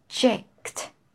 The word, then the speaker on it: subject: Sub. Subject, subject, subject. Stress first syllable.